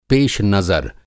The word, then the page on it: पेशनज़र: پیش نظر